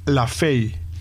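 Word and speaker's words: Lefebvre: Se llama Jim Lafay, no Jim LaFevre.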